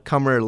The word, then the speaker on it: Kamar: It's a Kamar like summer.